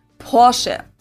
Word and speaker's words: Porsche: In German, we say Porsche. So there's like this short A sound in the end.